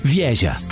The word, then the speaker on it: wieża: The word for tower, wieża.